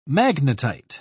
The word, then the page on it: magnetite: British English